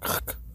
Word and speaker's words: Krk: Wie wir jetzt gelernt haben, wird es nicht Kirk ausgesprochen, sondern Krk.